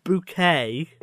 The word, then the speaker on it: Bucket: All right, so on the sitcom Keeping Up Appearances by Roy Clark, Hyacinth Bouquet is spelt bucket, but she insists it's pronounced bouquet.